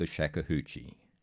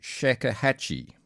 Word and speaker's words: shakuhachi: or Grandmaster of the Shakuhachi. Sorry, I'm mispronouncing that. Shakuhachi. Shakuhachi.